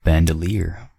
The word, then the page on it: bandoleer: American English